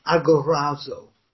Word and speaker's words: agorazo: The third one, a bit easier to pronounce in Latin, agorazo. A-G-O-R-A-Z-O. Agorazo. A-G-O-R-A-Z-O.